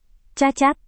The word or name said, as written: cacat